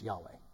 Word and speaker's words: Yahweh: You know, in the East, in the Hebrew tradition, the name for God is spelled YHWH. They removed the vowels to keep it sacred later in time. And it's pronounced Yod-Heh-Vav-Heh. We pronounce it in English, Yahweh.